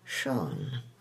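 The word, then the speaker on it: Siân: or first names like Sian, correctly pronounced Sean.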